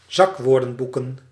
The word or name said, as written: zakwoordenboeken